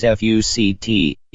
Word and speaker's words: FUCT: The legal battle had to do with a trademark application for a clothing line which is named by the letters F-U-C-T. Yes, it's pronounced by the individual letters, but it certainly looks and sounds like a rather common bad word if you pronounce it all together.